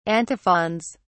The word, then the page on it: antiphons: British English